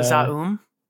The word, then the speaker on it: ZA/UM: The Z-A-U-M. – Oh, Zaum.